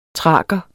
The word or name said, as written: thraker